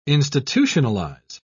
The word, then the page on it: institutionalise: British English